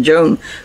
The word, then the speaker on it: Joan: For the purpose of the video and the audio, it's spelled J-O-A-N for the legal name.